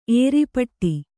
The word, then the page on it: ಏರ್ಪಾಟು: ērpāṭu